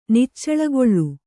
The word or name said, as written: ನಿಚ್ಚಳಗೊಳ್ಳು